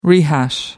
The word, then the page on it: rehash: American English